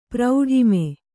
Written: ಪ್ರೌಢಿಮೆ

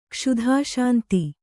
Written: ಕ್ಷುಧಾಶಾಂತಿ

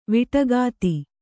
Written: ವಡಿಕತ್ತಿ

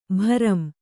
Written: ಭ್ರಮಿ

